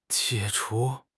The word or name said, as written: 解除